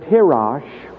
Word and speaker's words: tirosh: The second Hebrew word is the word tirosh, and that is spelled T-I-R-O-S-H. Tirosh.